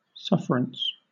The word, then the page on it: sufferance: British English